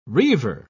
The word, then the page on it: reiver: British English